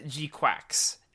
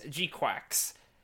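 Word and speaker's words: GQuuuuuuX: The agreed sort of pronunciation is G-Quacks. And they even say it in the show is G-Quacks.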